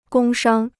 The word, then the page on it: 工商: gōng shāng